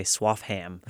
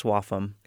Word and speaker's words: Swaffham: And every time I read it, I want to say Swaffham, but it's apparently pronounced Swaffham.